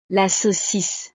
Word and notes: la saucisse: frances

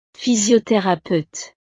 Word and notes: physiotherapeute: frances

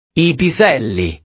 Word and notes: i piselli: italiano

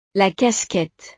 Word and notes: la casquette: frances

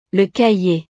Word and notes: le cahier: frances

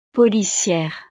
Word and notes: policiere: frances

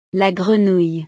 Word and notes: la grenouille: French

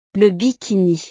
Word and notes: le bikini: frances

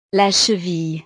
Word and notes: la cheville: frances

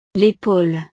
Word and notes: l'epaule: frances

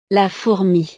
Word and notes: la fourmi: French

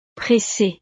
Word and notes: pressee: frances

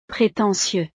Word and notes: pretentieux: frances

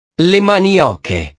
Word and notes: le manioche: italiano

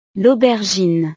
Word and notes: l'aubergine: frances

